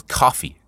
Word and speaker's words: Ko-fi: I think it's coffee, it's pronounced. K-O-F-I.